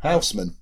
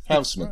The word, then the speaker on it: Housman: Housman, as you just did. Housman.